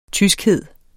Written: tyskhed

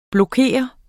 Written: blokere

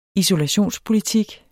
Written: isolationspolitik

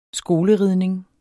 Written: skoleridning

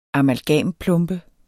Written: amalgamplombe

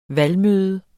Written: valgmøde

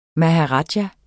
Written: maharaja

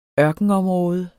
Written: ørkenområde

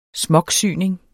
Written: smocksyning

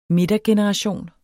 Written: midtergeneration

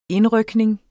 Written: indrykning

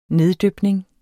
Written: neddypning

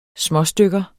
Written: småstykker

